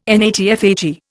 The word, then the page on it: NATFHE: Indian